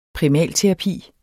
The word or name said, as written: primalterapi